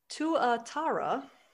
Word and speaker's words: Tuatara: I shall drop knowledge now, and it is pronounced Tuatara.